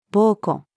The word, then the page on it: 鋩子: female